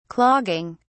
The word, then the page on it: clogging: British English